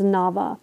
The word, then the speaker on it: Naba: Okay, so he's giving us the pronunciation as Naba.